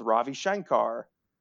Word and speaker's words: Ravi Shankar: I hung out with Ravi Shankar.